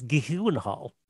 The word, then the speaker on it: Gyllenhaal: It's Gyllenhaal.